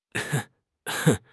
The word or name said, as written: 和解